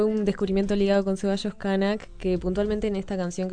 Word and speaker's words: Knak: Kanak. – Kanak. Bueno, yo me tenés que ayudar con las pronunciaciones, porque para mí... Kanak, ahí va. En realidad se escribe K-N-A-K, sí.